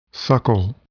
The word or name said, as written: suckle